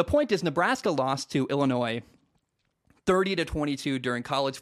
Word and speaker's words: Illinois: The way the state of Illinois spells things, the S is silent. I hate that so much. Anyway, why include the S, I guess, is my point. It's Illinois is how it's spelled, but it's Illinois is how you say it.